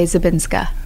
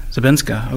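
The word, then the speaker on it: Żabińska: You're playing Antonina Zabinski. I hope I said that right. – Well, you say when it's the woman by herself, you say Zabinska. – Zabinska, okay.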